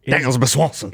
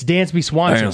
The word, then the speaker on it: Dansby Swanson: Dansby Swanson. – It's Dansby Swanson.